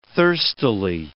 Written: thirstily

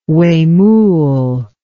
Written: huemul

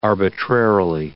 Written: arbitrarily

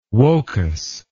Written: wocas

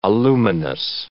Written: aluminous

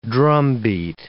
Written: drumbeat

